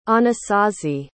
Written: Anasazi

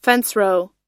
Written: fencerow

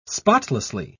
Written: spotlessly

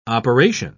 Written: operation

